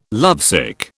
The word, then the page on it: lovesick: British English